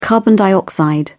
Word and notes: carbon dioxide: British English

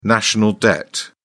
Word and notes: national debt: British English

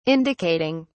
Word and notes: indicating: British English

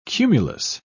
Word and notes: cumulous: British English

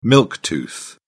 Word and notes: milk tooth: British English

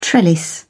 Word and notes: trellis: British English

